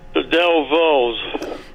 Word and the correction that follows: Dovells: I'm not saying it right. – That's all right. You know it's the Dovells, and I'll give it to you for that.